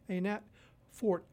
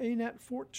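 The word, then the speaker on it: Anat Fort: Her first name is spelled A-N-A-T, Annette Fort, F-O-R-T, the Annette Fort Trio.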